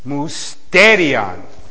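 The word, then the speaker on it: musterion (μυστήριον): Ta, not to, ta, musterion. Musterion. Ta, musterion. That's how it's originally pronounced.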